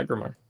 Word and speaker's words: Aggramar: Curse of Aggramar. – Yeah, Aggramar.